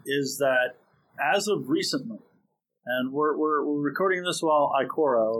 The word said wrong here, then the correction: Ikoria: or whatever, how do you pronounce it? – Ikoria.